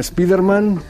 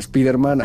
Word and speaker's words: Spider-Man: Abans en dèiem Spiderman, ara és... Una qüestió de pronúncia, en aquest cas. En dèiem Spider-Man.